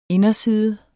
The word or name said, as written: inderside